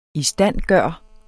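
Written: ISTANDGØRE

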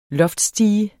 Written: loftstige

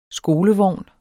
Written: skolevogn

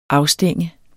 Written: afstænge

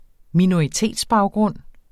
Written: minoritetsbaggrund

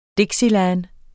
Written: dixieland

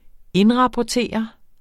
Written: indrapportere

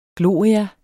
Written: gloria

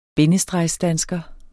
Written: bindestregsdansker

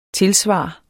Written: TILSVARENES

